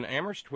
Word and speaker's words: Amherst: Thank you to Terry in Amherst, where only the H is silent.